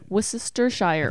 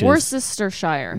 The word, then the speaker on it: Worcestershire: Worcestershire. – I think you're adding one too many SHs. – Worcestershire.